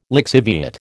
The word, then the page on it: lixiviate: American English